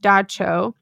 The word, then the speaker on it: Dubtsog: So one of these was a spirit, Da Cho, which is actually spelled D-U-B-T-S-O-G. So thank goodness the article included the pronunciation, but it's pronounced Da Cho.